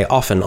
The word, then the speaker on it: often: they stopped pronouncing the T. They would say often, often, often. But then kind of like the regular people would say often. And this change sort of happened. It became common. And I think often without the T is more common.